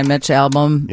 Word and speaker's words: Albom: Six People You Meet in Heaven by Mitch Albom.